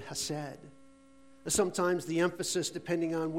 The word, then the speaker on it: hesed: Well, here, in this expression of the word love, we find a characteristic of God and his love in the translated word hesed, or hesed, however you want to say it. Hesed. I'm going to pronounce it hesed.